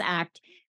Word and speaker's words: ACT: Acceptance and commitment therapy, or ACT, is pronounced ACT because it's about acting towards your values.